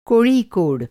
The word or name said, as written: कोड़िकोड